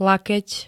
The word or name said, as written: lakeť